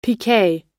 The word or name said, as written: piquet